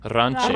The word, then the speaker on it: Rance: Jack Rance. – Jack Rance. – Oh, no, sorry. Ranche. – Ranche. Yeah. Yes.